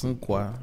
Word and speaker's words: Umpqua: That's Umpqua.